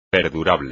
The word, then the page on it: perdurable: espagnol